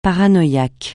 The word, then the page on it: paranoïaque: francais